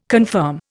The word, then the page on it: confirm: Indian